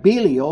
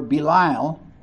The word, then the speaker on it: Belial: Belial is a Hebrew term that's translated here into the Greek, just taken into the Greek with the Hebrew pronunciation. But Belial or Belial meant someone who was worthless, this was the sum of wickedness to call somebody a person Belial.